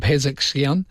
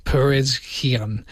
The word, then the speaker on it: Pezeshkian: The official IRNA news agency discussed that Khamenei and Pezeshkian had a 7-hour-long meeting to discuss the challenges facing the country. This is the President Masoud, and I'm pretty sure I said his name wrong there, Perezkian, who had the meeting about this.